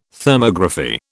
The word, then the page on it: Thermography: British English